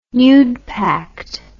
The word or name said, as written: nudepact